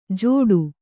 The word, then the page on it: ಜೋಡು: jōḍu